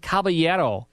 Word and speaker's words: caballero: The Cisco Kid was a heroic Mexican caballero.